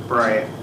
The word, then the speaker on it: Bryant: Hitano Pierre Bryant. Last name is B-R-Y-A-N-T. First name, G-I-T-A-N-O.